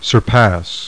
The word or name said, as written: SURPASS